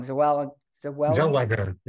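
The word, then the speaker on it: Renée Zellweger: Renee Zellweger.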